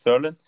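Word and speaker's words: Zuerlein: Kommen wir, oh, da wirst du mich jetzt verbessern in der Aussprache, oh, zur Nummer 5 von den Cowboys, Greg Zerlein. Zerlin? Ja, lasse ich gerade noch so gelten. Ah, okay, Greg Zerlin.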